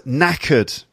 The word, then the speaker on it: knackered: And this is the word knackered. Knackered. And it's spelled K-N-A-C-K-E-R-E-D. So you've got a silent K.